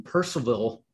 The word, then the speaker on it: Percival: Hung and April made their home in Percival. Did I say that right? – Yes, sir. Yep.